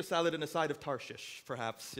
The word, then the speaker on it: Tarshish: And in case you're wondering, I did pronounce Tarshish correctly. It's one of those funny Bible words that doesn't sound right when you say it, Tarshish.